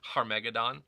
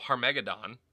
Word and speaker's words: Armageddon: Uh, in Hebrew, it would actually be uh, Armageddon, or you would actually have the uh, sound at the beginning, Harmegadon. So Harmegadon is how it would actually be said in Hebrew we Anglicanize it as Armageddon, and then uh, we make movies with uh, Ben Affleck and and Bruce Willis.